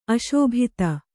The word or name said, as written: ಅಶೋಭಿತ